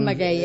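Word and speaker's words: Mackay: Tothom ho pronuncia com vol. Però nosaltres ell tots diem Mackey, eh? – Sí, ell ho pronuncia sí, Mackey, que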